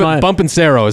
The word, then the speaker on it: Bonpensiero: Is his name Bumpensero is how it's pronounced on the show.